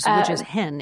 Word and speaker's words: hen: Which is hen, H-E-N. – Which is hen. Yeah, exactly. Pronounced like the bird in English.